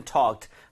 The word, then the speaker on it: talk: 자, 여기 지금 we haven't talked 하고 나서 in years 했습니다. 자, 일단은 talk는 얘기하다가 되겠죠? 그리고 발음할 때 talk이 아니라 talk입니다. 입 모양 조금 더 벌리셔야 돼요.